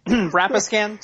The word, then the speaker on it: Rapiscan: Rapiscan, sorry. It's rapiscan. Even though it's spelled rapiscan.